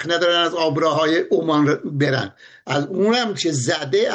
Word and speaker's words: عمان: بله من در فقط در حیطه تخصصم یه جمله بجم در باره تلفظ عمان، اما نیست تلفظ دولت کشور عمان بدون تشدید میمه،